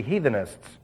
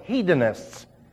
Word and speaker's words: hedonists: Now, immediately someone's going to say, well, you're pronouncing that wrong. It should be heathenists. No, it's hedonists. H-E-D-O-N-I-S-T-S. Hedon.